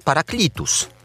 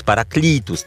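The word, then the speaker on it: paraclitus: Bo tutaj tłumaczy paraklitus, Hieronim. Prawda? E wymawiamy jak i, więc paraklitus tutaj akcent.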